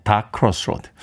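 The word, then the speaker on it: Tha Crossroads: 네, THA라고 발음을 하는데, 네, 다 크로스로드.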